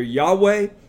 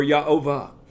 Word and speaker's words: Yahweh: and it's most likely pronounced either Yahweh or Yahovah.